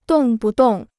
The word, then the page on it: 动不动: dòng bu dòng